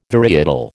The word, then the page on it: varietal: American English